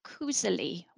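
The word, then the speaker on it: koselig: I was speaking to my Norwegian pal Pia, and she says that it's pronounced kuseli, which is kind of the equivalent. Both mean that cozy feeling.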